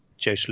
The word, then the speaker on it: Joost: If we go back to the winners, Ashin Wu and Mikko Kornhan both won in China and both perform well at KLM, as does Joost Luton, pronounce it toast.